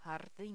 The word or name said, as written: jardin